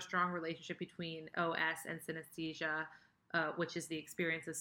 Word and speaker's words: synesthesia: synesthesia. I didn't say that. – Oh, synesthesia.